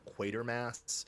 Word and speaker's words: Quatermass: I believe it's a reference to a British TV show that was later turned into movies called Quatermass. It's spelled with a T instead of a V. And so it's, you know, it's kind of like Quiver, kind of like Quatermass.